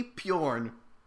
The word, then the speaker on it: Pjorn: Next one is Kitty Pjorn. – Oh. – Kitty Pjorn. – P-I-O-R-N? – P-J-O-R-N. It's oh, no, with a silent J.